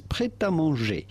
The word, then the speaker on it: prêt à manger: In French, we would say this, prêt à manger.